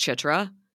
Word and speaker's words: Citra: By the way, I think her name is pronounced Chitra. that's the way it sounds like her family's pronouncing it.